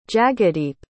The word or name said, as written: jagadeep